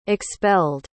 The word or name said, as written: expelled